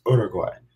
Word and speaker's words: Uruguay: They kept telling me I was saying it wrong. I was going Uruguay. I was like, no, it's Uruguay.